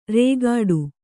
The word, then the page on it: ರೇಗಾಡು: rēgāḍu; Kannada